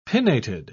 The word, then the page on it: pinnated: British English